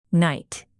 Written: night

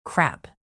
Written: crap

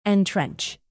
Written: entrench

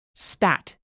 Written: spat

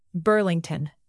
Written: burlington

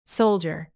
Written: soldier

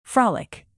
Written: frolic

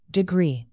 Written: degree

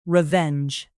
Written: revenge

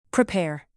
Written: prepare

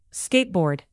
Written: skateboard

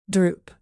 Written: droop

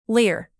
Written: lair

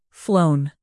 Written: flown

